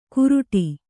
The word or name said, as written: ಕುರುಡಿ